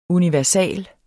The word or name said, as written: universal